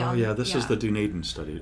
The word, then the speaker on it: Dunedin: We had a group before that used data out of the Dunedin study, and we didn't know how to say it, so we went on Google and clicked the button, and it said Dunedin.